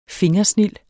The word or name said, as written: fingersnild